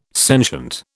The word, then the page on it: sentient: British English